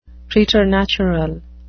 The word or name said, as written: preternatural